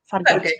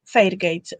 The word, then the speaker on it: Fairgate: jak poprawnie wymawia się Fargate. Fairgate, e, która została zabita w hotelu Oslo Plaza w 1995 roku i jest to bardzo tajemnicza śmierć, bo w zasadzie no nic to...